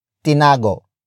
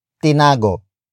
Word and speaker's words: tinago: Up next, we have tinago. Tinago. Tinago. Tinago is spelled as T-I-N-A-G-O.